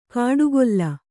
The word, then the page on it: ಕಾಡುಗೊಲ್ಲ: kāḍu golla